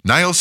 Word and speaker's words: Niles: Tu historia preferida, Box 8, Niles. Niles se escribe N-I-L-E-S, Michigan, 49120, Estados Unidos de América.